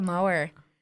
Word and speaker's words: Moher: Cliffs of Moher. Moher. – I think the H. I think the H is silent.